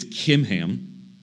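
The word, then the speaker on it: Chimham: I know it's pronounced, or it's spelled in the English C-H, but in the Hebrew, it's actually the guttural H, which would be a K. I can't do it well, but it's Kimham.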